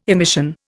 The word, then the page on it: emission: Indian